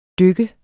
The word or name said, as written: dyk